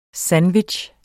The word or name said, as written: SANDWICHS